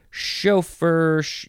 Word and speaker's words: chauffeur: Chauffeur, you can emphasize either syllable. Chauffeur, chauffeur. C-H-A-U-F-F-E-U-R.